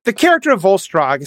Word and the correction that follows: Volstagg: especially as he's presented. – Volstagg. No, Volstagg. – Yeah. Volstagg. Thank you.